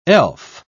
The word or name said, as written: elf